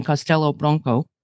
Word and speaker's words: Castelo Branco: Okay, you make a SH sound in the first word. Castelo, the okay. O is pronounced as a U. Castelo Branco.